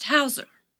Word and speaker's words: Heuser: Mrs. Hauser, Detective. H-E-U-S-E-R. But it's pronounced Hauser.